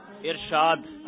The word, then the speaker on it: ارشاد: اب بتائیے صحیح تلفظ میں واکینٹ والوں کو کیا بتانا ہے, ارشاد یا ارشاد؟ – ارشاد. – اچھا جناب ارشاد فرمائیے, اور تسی ارشاد فرماؤ, تو ارشاد نہیں ہوتا ارشاد ہوتا ہے, اور ارشاد کا معنی ہے کیا معنی ہے؟ – حکم کیجئے.